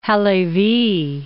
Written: Halevy

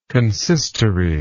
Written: consistory

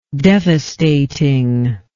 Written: devastating

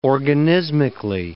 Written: organismically

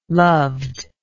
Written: loved

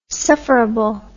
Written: sufferable

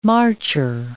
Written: marcher

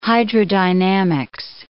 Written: hydrodynamics

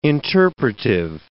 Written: interpretive

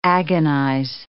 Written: agonize

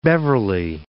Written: Beverly

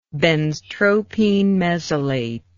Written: benztropine mesylate

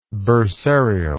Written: bursarial

